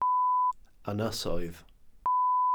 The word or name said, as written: ynysoedd